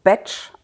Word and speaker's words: Bach: Genauer gesagt, der Name wird eigentlich Batch ausgesprochen, ach, wird aber wie das Worte, deutsche Wort Bach geschrieben. Deswegen sagt man in Deutschland Bach, weil er bzw. seine Familie sind Waliser gewesen und da wird halt Bach, also diese vier Buchstaben, als Batch ausgesprochen.